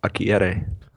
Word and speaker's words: Akureyri: We have Icelandic White Ale from Einstock Olgert in Akureyri, Iceland. And the Hitachino White Ale from Hitachino Nest Beer. It's called Kiyuchi Brewery in Japan. – Nice pronunciation on Akureyri.